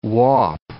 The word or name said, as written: whaup